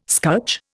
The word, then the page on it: scutch: Indian